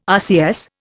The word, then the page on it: RCS: Indian